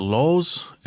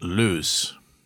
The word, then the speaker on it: Loos: The French pronounce it Loos, and the British called it Loos. Take your pick.